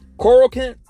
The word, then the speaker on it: chloroquine: Coracin. That's how you're supposed to pronounce it. Let me spell it for you. There's a drug called Coracin, which is C-H-L-O-R-O-Q-U-I-N-E.